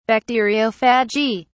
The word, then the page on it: bacteriophagy: British English